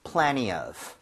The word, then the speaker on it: plenty of: 때에 따라서 여러분들 plenty 대신에 t를 약화시켜서 발음 안 하는 경우도 많아요. 그래서 plenty of. – Plenty of. – 그런 식으로 가도 괜찮습니다.